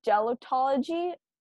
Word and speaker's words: gelotology: It's called gelotology. I'm not sure if I'm pronouncing that right, but I'll spell it out. G-E-L-O-T-O-L. I spelled that wrong. Wait, oh, I can't read. G-E-L-O-T-O-L-O-G-Y.